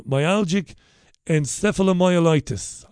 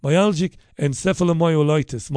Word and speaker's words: myalgic encephalomyelitis: This woman said that ear seeds, beads which stick to the ear, had helped her recovery from myalgic encephalomyelitis. I said it right first time. Give that boy a banana. Myalgic encephalomyelitis.